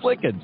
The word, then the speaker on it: Lykken: So he goes by Lichen, and I go by the way the proper Norwegian pronunciation, which is Lichen.